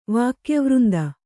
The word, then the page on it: ವಾಕ್ಯವೃಂದ: Kannada